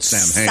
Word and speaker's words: Samhain: Spelled Samhain, but it's pronounced Samhain, yeah.